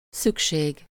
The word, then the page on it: besoin: [bə.zwɛ̃]; France; [bœzwæ̃]; /bzwɛ̃/